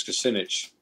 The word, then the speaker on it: Kucinich: Even though the guy I worked for was a guy called Dennis Kucinich, rhymes with spinach.